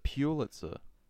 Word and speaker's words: Pulitzer: We're going through Kendrick Lamar, like trying to find, in honor of his Pulitzer Prize. – Yeah. – But I think he said Pulitzer Prize as well. – That's how you pronounce it. You pronounce it Pulitzer.